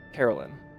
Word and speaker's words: Caralynn: Carolyn. C-A-R-A-L-Y-N-N.